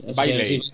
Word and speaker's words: Bailey: Entra el otro, el poli, para que el HG Bales este, para que Bales, he dicho, no, es Bailey. Es como el Bailey's, o sea, será, es G.W. Bailey, ¿no? Bien pronunciado sería así.